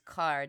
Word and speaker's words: Kerr: It's Carr, Deborah Carr. – I always do this. Yeah. Yes. – Yeah. It's fine. It's spelled that way.